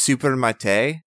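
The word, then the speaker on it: Supermartxe: I think it's just super mate, but I might be wrong. It's spelled uh uh like super m a r t x e. Mm, okay, that sounds right then.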